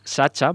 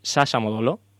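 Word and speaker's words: Sacha Modolo: Sasha Módolo, que no es Sacha, por lo que he podido escuchar en las cuando lo presentan, y eso es Sasha Módolo. Creo que más o menos Sasha Módolo es como se pronuncia.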